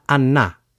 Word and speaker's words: annà: Andare diventa andà. – In realtà diventa annà, sempre per quella regola per cui mondo diventa monno.